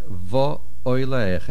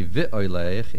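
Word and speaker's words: Vo Oilech (ואולך): And the last word, Vo Oilech, a komatz under the vav, which means and I led. If you say vi oilech, it would mean and I will lead.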